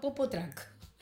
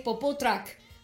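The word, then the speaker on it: Popotruck: Fíjate que tiene números de teléfono, tiene Instagram, yo lo estoy siguiendo, tiene un Instagram que es Popotrack. Así como lo ven ahí, es Popó, de Popó, chicos. Y truck se escribe con CK final. T-R-U-C-K final.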